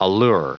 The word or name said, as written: allure